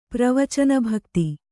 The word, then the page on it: ಪ್ರವಚನಭಕ್ತಿ: Kannada